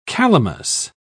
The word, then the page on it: calamus: British English